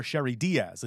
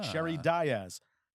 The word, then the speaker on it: Sheri Dias: I called her Sherry Diaz. It's not Diaz. It's Diaz. – Ah. – Sherry Diaz. She has handles.